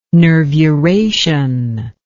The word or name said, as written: nervuration